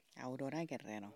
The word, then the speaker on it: Aurora Guerrero: Aurora Guerrero.